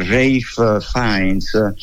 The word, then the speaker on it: Ralph Fiennes: fra l'altro è Ralph Fiennes, questa è la pronuncia del nome di Ralph Fiennes, così si scrive, sì, che è una bella qualità.